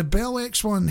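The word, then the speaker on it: Bell X-1: Bell X minus one aeroplane. Have I said that right? – The Bell X one.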